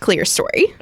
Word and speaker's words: Clerestory: It is pronounced Clerestory.